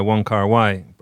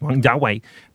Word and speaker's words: Wong Kar-wai: Yeah, it reminds me of one of my favorite movies is In the Mood for Love by Wong Kar-wai. Actually pronounced Wong Jiawei.